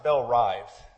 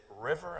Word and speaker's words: Belle-Rive: One correction, it's pronounced Belle-Rive. Most people, whenever I would call them a business or something, they say, is it Belle-Rive? Is it River? I'm like, no, there's not a missing R. So it's Belle-Rive.